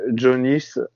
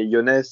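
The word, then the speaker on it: Jo Nijs: Donc, Yonez, c'est Jonis, si on, prend, si on prononce très mal son nom de famille. Jonis, c'est la version écrite et Yonès, c'est vraiment comment ça se prononce en flamand.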